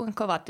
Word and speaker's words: Angkor Wat: Spricht man das richtig aus? Ist das Angkor, ja. Angkor Wat? Ja.